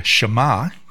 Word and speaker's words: shema: Throughout the pages of scripture, there's a word that gets repeated here, H-E-A-R, or a related word, listen. In the Old Testament, the Hebrew word shema, that's spelled S-H-E-M-A, shema, translates into English as hear or listen, and is often used to emphasize the act of listening, understanding, and obeying.